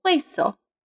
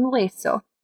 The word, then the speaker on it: hueso: Un hueso. Bone. Un hueso. With a silent H at the beginning.